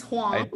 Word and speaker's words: Hoang: Did I say that right? – It's Hoang, but that's okay.